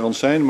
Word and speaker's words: Ransijn: En tenslotte, de heer, ik kan het niet goed lezen, Ram van jaar 21, Ransijn, zeg ik het goed? – Dat is meneer Ransijn, ja. – Ja, meneer Ransijn,